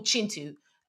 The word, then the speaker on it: Kintu: Um, the idea of the first man and uh, his experience on the earth, and it's called Chintu. Um, that's spelled K I N T U, and um, we pronounce our K's like chuz in Luganda and it centers uh, Chintu and the wife he eventually um, meets and falls in love with.